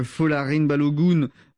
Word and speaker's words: Folarin Balogun: Donald Trump qui appelle la FIFA pour lever un carton rouge qui visait le meilleur buteur de l'équipe américaine, Folarin Balogun. Je le dis bien, Olivier Pron. – Impeccable.